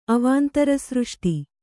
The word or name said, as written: ಅವಾಂತರಸೃಷ್ಟಿ